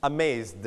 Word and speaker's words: amazed: เราจะบอกว่าเสียงเธอนะครับ past repeat after me past kissed missed ให้มีถึเบาๆถึนะครับ and quiz อันนี้เป็นตัวเสียงเสียงนะครับเสียง voice ครับ repeat after me quiz buzz amaze when you put ed you pronounce the quizzed buzzed Amazed. – Amazed.